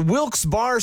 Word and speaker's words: Wilkes-Barre: And you're listening to Wilkes Barre Scranton's 97.9X. Now, I had heard that it was supposed to be pronounced Wilkes-Barre. Wilkes-Barre Scranton's 97.9X. The only way to pronounce Wilkes-Barre.